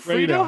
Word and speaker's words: Fredo: Fredo? How do you say it? – It's Fredo. He keeps saying Fredo.